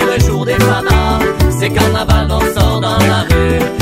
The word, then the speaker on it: carnaval: En occitan, ça s'écrit comme en français, carnaval, mais ça se prononce carnaval. Suffit de faire le V en be.